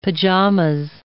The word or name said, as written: pajamas